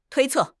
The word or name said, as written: 推测